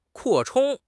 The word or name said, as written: 扩充